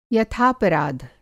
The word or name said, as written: यथापराध